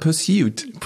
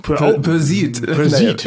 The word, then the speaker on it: Pursuit: Nee, Sie haben gesagt Pursuit. – Pursuit. – Ja, also mehr nach Persil als Pursuit.